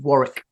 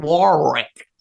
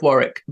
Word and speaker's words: Warwick: But you may have seen, if you're in the UK, I don't know if you know where there's a little place called Warwick. It's spelt Warwick. So if you're talking to an American, they'll go, "Ah, oh, we stayed in a great little place called Warwick."